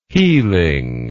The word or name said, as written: healing